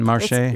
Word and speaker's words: marché: Coming up on next week's show, an introduction to La Marche Gourmande. – Okay, so just a little... – Oh, did I say it wrong again? Marché? – It's le marché. Yeah, accent aigu. Marché gourmand.